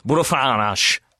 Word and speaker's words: Fernandes: It's pronounced Bruno Fernandes, by the way. – Bruno Fernandes. – Yeah, is that better? – Right.